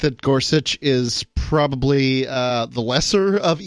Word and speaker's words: Gorsuch: I pronounce it Gorsuch.